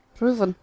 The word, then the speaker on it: Ruthin: How do you pronounce that in Welsh? – Ruthin.